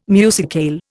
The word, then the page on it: musicale: Indian